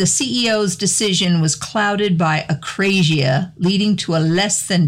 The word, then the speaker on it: akrasia: I picked acrasia. – Acrasia. – It's spelled A-K-R-A-S-I-A.